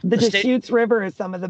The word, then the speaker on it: Deschutes: Deschutes. – Deschutes.